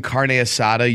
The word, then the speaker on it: carne asada: If any time you're saying carne asada, you roll your R. Yeah. Carne asada, you're like, oh, you're like a white dude with flannel and a beard. – You're supposed to roll the R at the beginning if it's more.